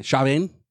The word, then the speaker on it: Chauvin: I don't even know how to pronounce his fucking last name, Chauvin? – Yeah, that name's weird. – Yeah, Chauvin.